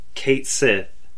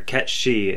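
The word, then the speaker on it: Cait Sith: Kate Sith is pronounced Kate Sith, or Ketshi is pronounced Kate Sith.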